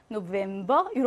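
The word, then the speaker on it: November: 근데 외래어에서는요, 브로 발음합니다. 클라비어, 노벤버 이렇게요.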